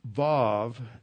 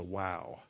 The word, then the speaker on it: Vav: The Vav, that's the middle letter, is pronounced like a V. One of the things that I always, when I was a kid, like some of you at the time, Pastor Thiem always pronounced it like a V. When I went to seminary, it's hard to say this as a W. And I've never understood that, because if you go to Israel, they always pronounce this as a Vav and not as a Wow.